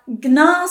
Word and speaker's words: Gnar: I was like, if you're not pronouncing the N, they're not even going to know there is one. So I thought it was really funny that he kept saying, because I was literally like, where would the N even go? – So it's spelled G-N-A-R, Gnar.